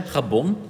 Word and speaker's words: Gabon: Dus ja, we beginnen hier met gewoon Gabon, zeg ik dat goed? – Hoor? Ja, Gabon.